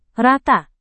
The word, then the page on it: rata: Indonesian